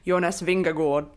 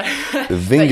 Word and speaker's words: Vingegaard: I actually messaged my Danish teammate, Emma Norsgaard, during the stage to ask her to send me a voice note of the pronunciation, which I think is Jonas Vingegaard.